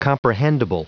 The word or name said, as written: comprehendible